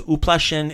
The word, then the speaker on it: uplašen: So uplašen is the male word, uplašen? – Uplašen. – So uplašen. Remember the U is on the first syllable, U.